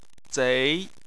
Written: zei2

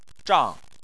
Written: zhang4

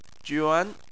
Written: juan2

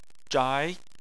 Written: zhai2

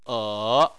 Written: e3

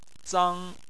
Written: zang1

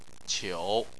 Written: qiu3

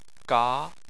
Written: ga2

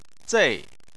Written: zei4

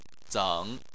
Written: zeng3

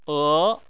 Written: e2

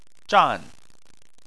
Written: zhan4

